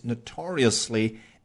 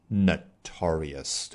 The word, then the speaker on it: notorious: 好，在刚刚举的这些例子当中，你都听出了那个发音是 notorious，对不对？或者 notoriously。哎，如果你在第一个音节上稍微加一点点重音，就变成了 notorious 或者 notoriously。为什么要加重音？有的时候，因为你要再强调一下这个程度嘛。所以 notorious、 notorious 都可以。 That is to say, if you put a slight stress on the first syllable, it's notorious. 啊，刚刚我用英文再总结了一下而已。来，回到原文，show business can be notoriously unforgiving in its treatment of someone 啊，后面这个 in 指的就是在什么什么方面啊，这个娱乐圈它是非常严酷的一个环境。